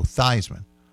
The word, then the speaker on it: Theismann: But most people throughout his career, even going back to his days at Notre Dame, knew him as Joe Theismann. Theismann rhymes with Heisman. There's some suggestion that that's even why he pronounced it that way.